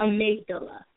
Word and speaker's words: Amygdala: How do you say that word? – Amygdala.